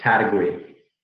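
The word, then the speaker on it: Taitague: It's Tataguay. – Tataguay. There we go. – Tataguay. All right. – In Guam, where it's from, they usually say Tataguay, which actually a lot of Germans say.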